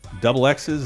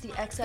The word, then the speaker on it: The XX: And the Double X's, is that how you say your name? Their self-titled debut? – It's just the XX.